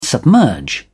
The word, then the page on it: submerge: British English